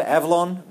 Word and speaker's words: Avalon: Remember, Avalon rhymes with Babylon.